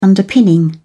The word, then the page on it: underpinning: British English